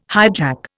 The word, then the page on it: hijack: Indian